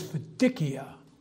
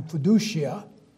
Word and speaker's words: fiducia: The Latin word is fiducia, or if you like the English pronunciation of Latin, fiducia, faithfulness.